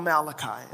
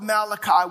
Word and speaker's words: Malachi: Now, the Old Testament ends in the book called Malachi.